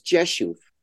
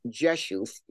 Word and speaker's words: Rzeszów: which is Rzeszów. I had a cousin who used to live there, and it's spelled R-Z-E-S. Z-O-W, but it's pronounced Rzeszów. You know, don't ask me why. That's the Polish pronunciation.